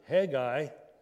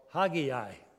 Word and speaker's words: Haggai: How do you pronounce this book? And the answer is both. You can pronounce it Haggai or Haggai. I kind of like the second one. It's kind of like Malachi instead of Malachi. But for what it means, I'll go with Haggai unless I forgot and refer to the other one.